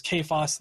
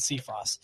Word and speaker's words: Cephas: I've heard it pronounced both ways, so stop giving me shit about how not you specifically, but I've seen it expressed both ways, Cephas and Cephas.